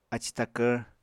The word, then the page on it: aci takvr: Apatani